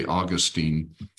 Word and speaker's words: Augustine: Augustine, some people say Augustine, depending on where you put the emphasis on which syllable.